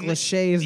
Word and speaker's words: Lachey: I'm really embarrassed that I didn't know how to say New Hampshire, but I do know how to pronounce Nick Lachey's name. – Yeah, let me see how it's spelled, though. – L-A-C-H-E-Y, Lachey.